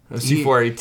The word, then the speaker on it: C418: This is originally composed by Daniel Rosenfeld, also known as C418. – No, C418. – C418. – He insists upon that pronunciation.